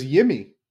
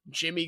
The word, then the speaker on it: Yimi: Yeah, oh yeah, Jimmy. – I thought it was Yimi. – That's how it's spelled. Pronounced Jimmy.